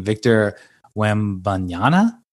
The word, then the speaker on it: Victor Wembanyama: Victor Wembanyama.